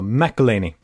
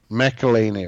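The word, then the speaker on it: McElhinney: Mac ja, jag har lärt mig uttala nu i och med att han har kommit till Tampa. McElhaney. McElhaney, ja.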